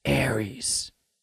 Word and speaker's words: Ares: And I believe, actually, I've been saying it wrong. You're supposed to call it Tron Ares. Ares.